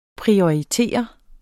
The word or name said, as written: prioritere